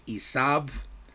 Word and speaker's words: Esav: And again, Esav is the Hebrew pronunciation of the name that we know as Esau.